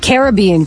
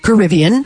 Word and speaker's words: Caribbean: That's Caribbean Queen or Caribbean. – Yeah. – Depending on how you say it.